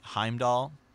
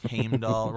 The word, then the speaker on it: Heimdallr: And so in Norse, you know, mythology, if the letter R comes after a vowel, you pronounce it, kind of like tear, right? Or like Thor. But if it comes after a consonant, it's silent. So like Heimdall. – Yeah. – Like, you know, like Heimdall, right? And you know, it's spelled like Heimdallr.